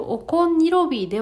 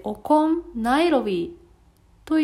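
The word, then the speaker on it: okoń nilowy: この魚って何えそうすると、そもそも私の読み方が間違っていまして、えっ、ー、と、おこんにロビーではなくて、おこんナイロビーというのが正しい発音だったようです。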